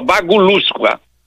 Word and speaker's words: bagulusca: Babulusca, professor, a origem é essa? – É com G de O, bagulusca.